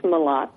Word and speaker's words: Mellott: It's Mellott.